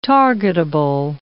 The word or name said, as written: targetable